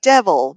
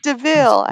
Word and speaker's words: DeVille: When they steal, she steals the car, and he's like, it looks like devil, but it's pronounced DeVille.